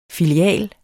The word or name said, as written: FILIALEN